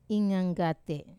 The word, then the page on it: nganggat: wamesa